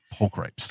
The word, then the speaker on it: pulchripes: I always mispronounce that one. It's uh pulchripes.